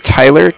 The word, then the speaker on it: Tyler: My name is Tyler, T-Y-L-E-R, okay. – And I'm calling with Cardiff. It's spelled C-A-R-D-I-F-F, as in Frank.